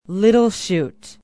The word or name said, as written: little chute